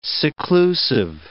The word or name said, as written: seclusive